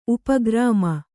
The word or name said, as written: ಉಪಗ್ರಾಮ